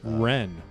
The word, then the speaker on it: Rennes: So, Rennes, who are they? – I think a French team. Oh. It's spelled R-E-N-N-E-S. I actually had to look up the pronunciation.